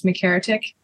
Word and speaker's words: McHeretic: Hey, so my Instagram is McHeretic, like MC Heretic. A lot of people pronounce it McHeretic, Michery or whatever.